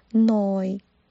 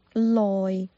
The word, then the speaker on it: 耐: 也可以发成 loi，是 l oi loi loi。如果这几期有听我节目的朋友就应该知道了。有些发音我们可以接受呢和了，所以在这里 noi 和 loi 我们都可以接受。